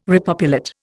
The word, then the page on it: repopulate: Indian